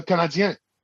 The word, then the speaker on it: Canadiens: They're not called the Canadiens. They're called the Canadiens. It's spelled I-E-N-S for a reason.